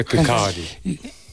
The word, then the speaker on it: Kirkcaldy: Se pronuncia Kirkcaldy. – Kirkcaldy.